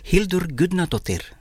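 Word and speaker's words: Hildur Guðnadóttir: Hildur Gudnadottir. – Hildur Gudnadottir.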